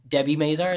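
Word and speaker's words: Debi Mazar: So that's all they wanted of Debbie Mazar, as I say that right? – Yep.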